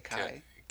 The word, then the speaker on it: Kaj: The little kid here, I'm not sure how to pronounce this unless it's Kai Erik Erickson. It's like K-A-J. – Yeah, I would say Kai.